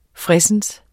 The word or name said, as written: fredsens